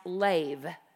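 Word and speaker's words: lev (לב): And this particular verse is using a Hebrew word that is pronounced lev.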